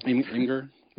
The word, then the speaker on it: Imgur: Imgur? Yeah, it's like GIF, you know. Right. That's the right way to say it if you don't say it that way. You're wrong.